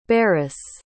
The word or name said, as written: beres